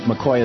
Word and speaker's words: McCaughey: Her last name, McCoy, is spelled M-C-C-A-U-G-H-E-Y.